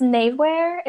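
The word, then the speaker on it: Knavewear: It's Navewear. It's Knave. – Ah, yes. – Wear. – The K is silent, so I'm glad.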